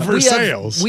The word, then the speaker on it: Versailles: It's like there's a town in Kentucky, Aaron, Versailles. – Yes. – And we call it Versailles. – No. – Making up stuff. – No, I'm serious. We call it Versailles.